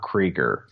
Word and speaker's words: Krieger: Hey, could you copy that URL and tweet it to Rob Krieger, rhymes with Seeker?